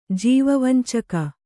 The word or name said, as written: ಜೀವವಂಚಕ